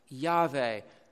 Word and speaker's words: Yahweh: what's transliterated in some parts of King James Version as Jehovah, was probably pronounced something like Yahweh, coming from the Hebrew word for to be.